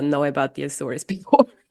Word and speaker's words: Azores: I don't know how to say this. – Azores. – Azores.